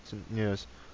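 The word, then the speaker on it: Tourneur: It was, as I suspected from the trailer, a riff on Tourneurs. Am I pronouncing it? Tourneurs? Tourneurs. Yeah.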